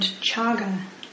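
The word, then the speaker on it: cāga: So he used a different word for this, and that word is the Pali word chaga, spelled C-A-G-A, and C is pronounced as C-H in Pali.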